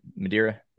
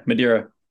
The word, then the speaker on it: Madeira: But that said, prior to Madeira, am I saying it right? Madeira? – Yeah, Madeira. – Madeira. Okay.